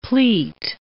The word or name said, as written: pleat